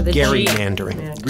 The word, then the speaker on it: gerrymandering: Is it gerrymandering? – Gerrymandering? – That's the correct pronunciation is gerrymandering. It's a hard G.